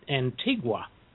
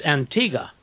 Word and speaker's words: Antigua: Well, you know, I was corrected when I used to call it Antigua. It's Antigua.